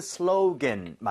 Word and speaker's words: slogan: 하지만 발음은 슬로겐.